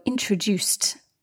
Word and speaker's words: introduced: So introduced, the ED is pronounced as T, not D or ID.